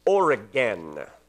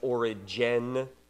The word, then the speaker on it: Origen: His name is Origen, not like our state. It's usually today pronounced Origen, because they've softened the G over time, but it's O-R-I-G-E-N.